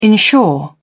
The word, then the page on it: ensure: British English